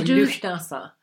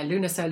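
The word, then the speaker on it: Lughnasa: So you do call it this time, you mentioned it on Facebook, a lovely name beginning with L that I Lunasa. – Can't... – Lunasa. – Lunasa. – Yeah, or Lufnasa. Not quite... Yeah, Lunasa, Lufnasa, I've heard it pronounced different ways.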